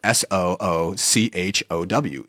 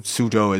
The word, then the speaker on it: Suzhou: Suzhou used to be spelled S O O C H O W. Soochow. – Oh yeah. Oh. The old romanization of things, uh. which is a ridiculous way to spell it if you know Suzhou is how it's pronounced uh, but like Soochow University is how it was, and like Szechuan food.